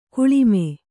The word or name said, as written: ಕೆಳಮೈ